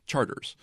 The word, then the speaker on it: Chartres: Charters.